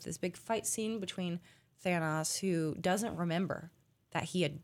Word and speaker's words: Thanos: Basically, Thanos, however you want to pronounce it, is Thanos. – They say it Thanos in the movie.